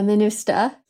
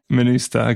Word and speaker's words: MINUSTAH: Yeah, MINUSTAH. – MINUSTAH, okay.